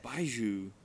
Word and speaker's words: Baijiu: Baijiu.